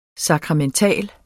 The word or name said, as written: SAKRAMENTALT